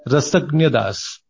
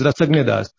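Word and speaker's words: Rasajna Das: It is actually R-A-S-A-J-N-A, and it is pronounced as Rasagna Das.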